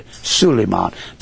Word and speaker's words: Suleiman: They spell Solomon S-U-L-E-I-M-O-N in Arabia, or M-A-N in Arabia, Suleiman. That's the way Burl Ives kept pronouncing it in the movie, Suleiman and the Blue Jinn.